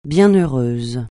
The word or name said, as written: bienheureuse